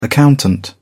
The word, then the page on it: accountant: British English